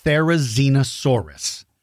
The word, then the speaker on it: Therizinosaurus: And by the way, it is pronounced Therizinosaurus. Therizinosaurus.